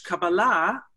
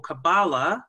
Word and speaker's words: Kabbalah: And once you hit mystical traditions, which Kabbalah or Kabbalah, uh, you can pronounce it either way, um, is the Jewish mysticism.